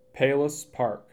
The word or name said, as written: Palos Park